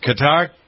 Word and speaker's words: Qatar: What's going on in the Middle East, especially the focus on Qatar? How do we pronounce this? Qatar? – Qatar. – Qatar. Qatar. Okay.